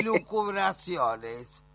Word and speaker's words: lucubraciones: Y hacer culubraciones, que tampoco me sale la palabra, ¿cómo se dice? – Escarola y lucubraciones.